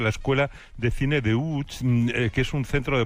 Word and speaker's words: Łódź: Aunque se pronuncia Łódź, se escribe Lodz, por si alguien lo busca. – Lodz, Google. Casi no tiene nada que ver la pronunciación.